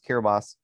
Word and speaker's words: Kiribati: which is looks like it should be spelled Kiribati, but it's pronounced Kiribati,